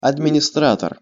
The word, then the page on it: administrator: Russian